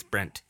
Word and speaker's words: Brent: No, but we meet Brett. He's excited. – Brent. – Brent. Did I say it like? – You keep saying Brett. It's Brent.